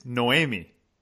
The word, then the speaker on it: Noemi: Okay, so if you don't know Mimi, that it's Noemi. Did I say that properly? – Perfectly.